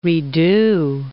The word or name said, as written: redo